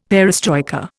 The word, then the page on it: perestroika: Indian